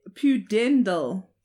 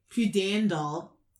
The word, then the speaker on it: pudendal: Pudendal. – Pudendal.